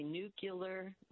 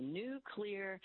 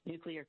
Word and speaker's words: nuclear: And then the third one that people mispronounce all the time is when they say nuclear, not correct. So if you, if it's cloudy today, and maybe, but tomorrow it's going to be a new clear day. Just say new clear, and you will pronounce nuclear correctly.